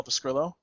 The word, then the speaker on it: Pescrillo: All right, and you can find me, as always, on Twitter at Paul Pescrillo. My last name is spelled P-E-S-C-R-I-L-L-O.